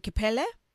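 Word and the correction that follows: Kpelle: It's called Fele. Yeah, Fele.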